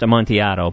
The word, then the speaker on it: Amontillado: And I guess it's supposed to be pronounced Amontillado, but yeah, I think we're going to recreate that.